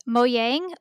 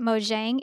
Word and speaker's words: Mojang: So the development company that made Minecraft is Mojang, or you can pronounce it Mojang if you'd like. There are two different pronunciations for it. Either are accepted by the company, so you can choose whichever.